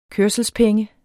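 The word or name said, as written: kørselspenge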